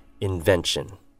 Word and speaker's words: invention: The first word being invention. Invention. Break it down into each syllable. Invention. T-I-O-N is pronounced as shun. Stress the syllable right before the shin as well, okay? So it becomes invention. Invention.